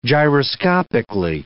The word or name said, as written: gyroscopically